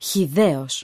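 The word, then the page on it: χυδαίος: Greek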